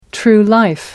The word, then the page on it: true life: American English